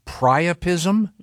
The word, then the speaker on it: priapism: And they would get, is it pronounced priapism? – Mm-hmm. Yeah.